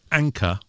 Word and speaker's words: Anker: Gadget of the week is from a company called Anker, which is spelt A-N-K-E-R. Not like the butter, I think. – No. – It's called the Soundbuds Lite, L-I-T-E.